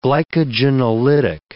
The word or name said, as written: glycogenolytic